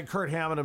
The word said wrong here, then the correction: Kirk Hammett: of Kirk Hammack of Kirk Hammett. – I can't say his name.